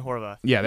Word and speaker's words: Horvath: Goalkeepers, Brad Guzan, Tim Howard, and I don't even know how to pronounce this guy's name. It's cutoffs here. And Ethan Horvath. – Yeah, there you go.